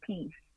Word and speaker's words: PSE: My middle name begins with an S and then E, but it's pronounced Peace. So I named the album PSE, Pronounced Peace.